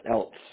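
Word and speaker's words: Elsts: The first S is silent, else.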